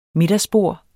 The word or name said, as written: middagsbord